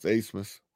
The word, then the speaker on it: Abmas: It's spelled A B M A S, but it's pronounced Asmus.